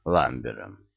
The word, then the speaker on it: Ламбер: «Я смотрел фильм «Сицилиец» с Кристофером Ламбером. Культовый актер. Он француз и американец». Гардиан улыбнулся кончиками губ. «Не знали? А ударение надо ставить на последнем слоге по-французски «Ламбер».